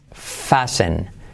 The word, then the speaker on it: fasten: Ahí tienes número dos, número tres palabras que no pronunciamos la T es fasen, fasen, fasen.